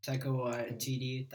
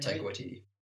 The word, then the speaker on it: Taika Waititi: Awesome movie, great humor, Taika Waititi, that's how you pronounce his name, Taika Waititi.